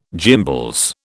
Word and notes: gimbals: British English

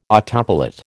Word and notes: autopilot: American English

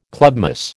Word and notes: clubmoss: American English

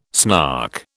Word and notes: snark: British English

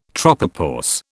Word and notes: tropopause: British English

